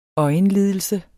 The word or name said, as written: øjenlidelse